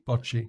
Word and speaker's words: bocce: Is it called bocce? – Bocce.